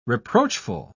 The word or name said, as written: reproachful